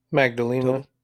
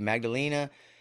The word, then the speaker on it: Magdalena: Magda. – I say that wrong, right? – Magdalena. – Thank you, Magdalena.